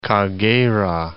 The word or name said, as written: Kagera